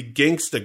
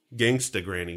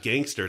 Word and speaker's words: Gangsta Granny: But no, the best I could find was that he was in the movie Gangsta Granny. Gangsta Granny. – There's a movie called Gangsta Granny? – Gangsta Granny. You're not even saying it right. It's not gangster. It's like gangsta. I'm a gangsta.